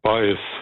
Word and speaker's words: Baez: Sei mit dieser Joan Baez oder Baez? – Baez, gell, ja. – So. – Latinisch ausgesprochen.